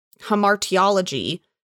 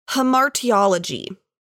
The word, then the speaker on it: hamartiology: Well, so the study of sin, hamartiology. – Wait, say that word again. I've never heard this word. – Hamartiology. – Hamartiology. – Yeah, okay. It's spelled H-A-M-A-R-T-I-O-L-O-G-Y.